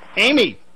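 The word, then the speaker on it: Aimee: Emmy, am I pronouncing your name correctly? – Yeah, sure, Amy. – Amy. – I know, it's spelled the French way, but I'm not French, so it's kind of...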